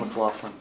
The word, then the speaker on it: McLaughlin: Hi, I'm Dan McLaughlin. I work for S&H. With the, I'm sorry, can you say your name again? – It's Dan McLaughlin. – Dan, okay. – Can you spell McLaughlin for us? – MC, capital L, A-U-G-H-L-I-N.